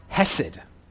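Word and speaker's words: hesed (חסד): In Hebrew, it's pronounced hesed.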